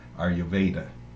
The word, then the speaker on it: Ayurveda: And we're living up to our title of our podcast, Yoga and Stuff, by today focusing on yoga and Ayurveda. – Correct. Well pronounced.